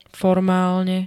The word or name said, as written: formálne